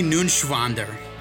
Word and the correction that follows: Neuenschwander: You didn't pronounce it. That's up. Anthony? – Anthony Nunschwander. – It's N-E-U-E-N. It's Neuenschwander. Anthony Neuenschwander.